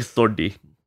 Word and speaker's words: Sodhi: I think it's like Sodhi. Like a DH makes like its own D. I don't know. You're asking the worst person. Like my dad can explain it. I'm Nav Sodhi.